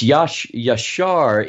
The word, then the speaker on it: Yashar: Now, speaking of pronunciation, in Hebrew, is it Yasher? – It's Yashar is the Yashar.